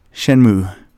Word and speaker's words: Shenmue: Shenmue.